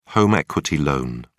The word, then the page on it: home equity loan: British English